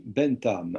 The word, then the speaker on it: Bentham: Il y a deux fondateurs importants, qui sont des philosophes moralistes, John Stuart Mill, M-I-2-L, John Stuart Mill et Jeremy Bentham. Alors, si bien prononcé en anglais, on met la langue là, ça donne Bentham, Jeremy Bentham.